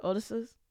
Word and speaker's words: ODESZA: Odessa's? – Who? – O-D-E-S-Z-A. Pronounce it. – O-D-S-Z? – O-D-E-S-Z-A. It sounds like old scissor.